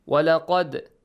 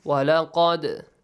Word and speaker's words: ولقد: The little dal must be pronounced correctly with an echoing sound. ولقد. The qaf is a heavy sound. Sometimes Hussein Ruhullah forgets this qaf, okay, to pronounce it heavily.